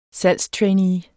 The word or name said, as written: salgstrainee